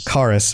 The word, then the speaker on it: Charis: So one pronunciation. – What was the pronunciation then? Charis. Yeah. Okay, so it's not a CH, it's a C sound. – No, I refuse. – Charis, because it looks like charis, right? – Yeah.